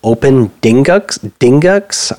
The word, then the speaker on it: OpenDingux: Kind of in related news, it also got ported to OpenDingux. I can't pronounce that. D-I-N-G-U-X. It's like dingus with an X, which is an older Linux that runs on like older Raspberry Pi type Alibaba type systems.